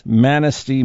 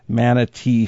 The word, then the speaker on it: Manistee: We talked about this silent STA item last month, and it is Manistee, Michigan, not Manatee, Florida.